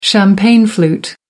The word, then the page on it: champagne flute: American English